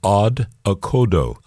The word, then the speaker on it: Odd Okodo: And they, in fact, are called Odd Okodo.